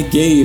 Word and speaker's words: Gaye: Aí eu falei, ah, não, é gay, mas é a pronúncia, né? Ele termina com E, mas é, o E é mudo.